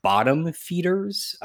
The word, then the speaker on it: BTTMFDRS: It's pronounced Bottom Feeders, but it's spelled BTTMFDRS.